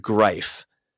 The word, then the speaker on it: Greif: Oh gosh, I think it's pronounced Greif. – Greif. – I think it's pronounced Greif, but it looks like grief, and it's so on point for the Jackets to name a sponsor.